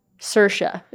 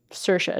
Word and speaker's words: Saoirse: Saoirse is how you pronounce her name. Saoirse, it's like inertia.